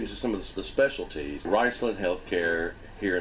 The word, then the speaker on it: Riceland Heathcare: But today we're talking about Riceland Heathcare.